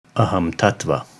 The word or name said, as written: Ahamtattva